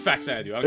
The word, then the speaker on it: Faxanadu: You mean Fazanadu? – Fazanadu. – Faxanadu. – Fazanadu? – Fuck that. – Fazadu. – I don't care how people pronounce it. It's spelled Faxanadu.